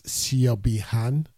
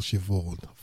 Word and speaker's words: Siobhan: That was Jason Ribello from his album called A Clearer View, and that's Siobhi Han. Actually, it's called Siobhan. That's the way it's spelled. S-I-O-B-H-A-N. Siobhan.